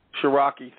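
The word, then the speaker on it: Sharroky: That's how do you pronounce his first name? Shiraki. Thank you. I was going to put a couple L's in there.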